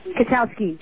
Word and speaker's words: Katowski: So okay, Katowski. – Katowski. I don't know why I keep pronouncing all those silent letters there.